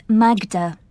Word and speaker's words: Magda: It's Magda. – How do you spell that? – M-A-G-D-A.